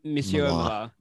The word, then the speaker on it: Monsieur Mala: I think it's Marsala. I can never pronounce his name. They're actually lovers in the comics. Yeah, I know, very saucy. But it's Monsieur Mala. – Monsieur Mala, yes.